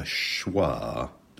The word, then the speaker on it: schwa: The sound that you get in above. That sound is called a schwa. That's S-C-H-W-A.